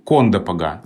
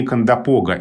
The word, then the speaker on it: Кондопога: А в литературном языке более привычно ударение Кондопога. И тогда вот как раз столкнулись эти два варианта. И при том, что словарь имен собственных Флоренции Леонидовны Агеенко говорит о варианте Кондопога, как в основном, он отмечает такое побочное ударение на первом слоге. То есть все-таки там два ударения на самом деле. Просто для литературного языка основное ударение в конце слова, а для речи местных жителей в начале.